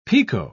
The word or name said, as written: picot